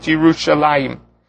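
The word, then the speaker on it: Yerushalayim (ירושלים): And he notes that many places throughout Tanakh, almost exclusively, Yerushalayim is always written chaser. There is no yud in it. In a couple of places, it's pronounced even as a chaser. But in the majority of the places, it's almost like a Korean active that even though it's spelled chaser, we still pronounce it Yerushalayim.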